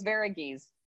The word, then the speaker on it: Varughese: You were very close. Varughese.